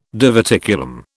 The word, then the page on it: diverticulum: British English